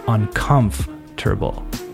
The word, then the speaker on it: uncomfortable: What you want to do is divide it into four different parts uncomfortable, uncomfortable, with the emphasis on the second part, uncomfortable.